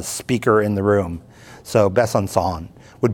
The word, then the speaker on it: Besançon: My sister tells me that C with a little funny hook on it is pronounced like an S. So I guess you would say Ben Besançon or something like that.